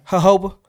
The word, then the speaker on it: jojoba: The G-O-J-I. – Jojoba. – G-O-J-I, what's that? – I can't pronounce it. It's jojoba. It's a weird ass way. It's like, bro, what? Jojoba oil. That's what it is. It's a J, yeah, but it sounds like an H.